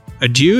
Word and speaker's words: adieu: James, do you want to bid us adieu? Adieu? Is that how you say it? – Very good. Very good.